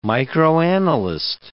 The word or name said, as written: microanalyst